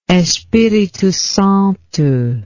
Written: espiritosanto

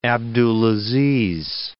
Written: Abdulaziz

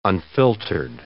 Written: unfiltered

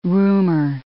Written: rumor

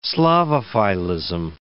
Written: Slavophilism

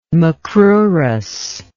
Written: macrurous

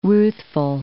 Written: ruthful

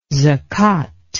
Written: zakat

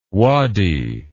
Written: wady

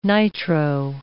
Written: nitro

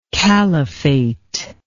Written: kalifate

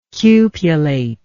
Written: cupulate

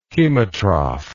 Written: chemotroph

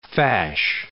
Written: fash

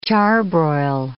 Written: charbroil

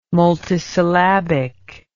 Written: multisyllabic

